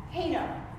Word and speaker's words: Heino: The next person that I would like to introduce is historic romance novelist Susan Gee Heino. Yes, I said it right.